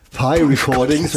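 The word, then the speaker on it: Pi Recordings: Ich habe mich eben versucht zu recherchieren, wie das P-Recordings auf Englisch ausgesprochen wird. Und es ist tatsächlich so wie der Kuchen? – Der Kuchen. – Also es ist Pi-Recordings.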